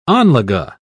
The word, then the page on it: anlage: British English